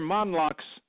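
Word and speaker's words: Mondloch: It is Dr. Monlock's name and followed by some initials.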